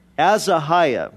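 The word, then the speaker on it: Ahaziah: It says, Moab rebelled against Israel after the death of Ahab, and now Azahiah, that's how you say his name, fell through the lattice of his upper room in Samaria and was injured.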